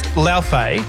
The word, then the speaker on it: Laufey: Now, the pronunciation is a bit of a tricky one. It's Laufey.